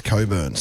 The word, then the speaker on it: Cockburn: Because Cockburn would be, it's spelt Cockburn, but it's, I think it might be pronounced Coburn sometimes.